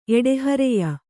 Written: ಎಡೆಹರೆಯ

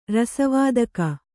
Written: ರಸವಾದಕ

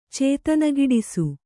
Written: ಚೇತನಗಿಡಿಸು

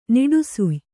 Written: ನಿಡುಸುಯ್

